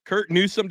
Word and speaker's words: Curt Newsome: Everybody, we've got Curt Newsome.